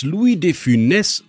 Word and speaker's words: Louis de Funès: Dass Louis de Funès, und ja, genau so wird er ausgesprochen, gar keine andere Wahl hatte, als Komiker zu werden.